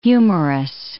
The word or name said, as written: humorous